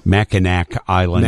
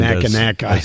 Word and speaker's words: Mackinac Island: Mackinac Island. – Mackinac Island.